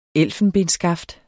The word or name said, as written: elfenbensskaft